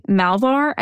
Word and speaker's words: Malvar: uh, there was one tip that uh, started with the disappearance of Marie Malvar, I think is how you say her name, M A L V A R.